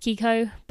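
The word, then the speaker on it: Chicco: On that note, just because I know a lot of people ask me, I have the Kiko or Chico, said pronounced Kiko, but spelt Chico, if you guys know what that means.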